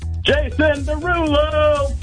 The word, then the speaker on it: Jason Derulo: Jason Derulo.